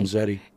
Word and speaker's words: Zenzedi: They say it's an ADHD and narcolepsy medication called Zenzedi, Z-E-N-Z-E-D-I.